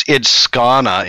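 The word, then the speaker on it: Skåne: It's Skana, in case you guys have forgotten. – she said Skana.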